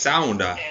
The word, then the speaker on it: sauna: Has really, really been sort of quite strict on how we pronounce the word sauna. – It's sauna. No, I got that from Kim.